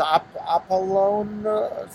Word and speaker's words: Apollon: I can't pronounce it. It starts A-P-O-L-L-O-N. – Yeah. – Something like Apollon. – That. – A-P-O-L-L-O-N. Right? – Yeah. – There we go. So Apollon. That's it.